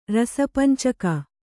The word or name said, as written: ರಸಪಂಚಕ